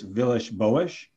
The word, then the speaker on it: Villas-Boas: I know Andreas Villas-Boas. – Yeah, great pronunciation. – Well done, I tried so hard to say that right.